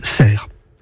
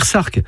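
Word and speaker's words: Sercq: Cela montre que la prononciation originelle était Cerque, mais que dès cette époque, la consonne finale n'a plus été articulée et que l'on a dit ensuite Cerre. C'est la même chose dans des noms communs comme Port ou Claire. Ils s'écrivent avec un C, mais il est muet. J'ajoute que la forme anglaise du nom de l'île s'écrit avec un A. Mais ce n'est pas pour cela qu'il faut dire Sark.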